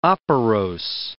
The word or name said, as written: operose